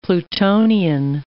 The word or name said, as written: plutonian